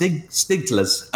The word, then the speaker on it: Stiglitz: Nobel Prize winner, and his name is pronounced Stiglitz.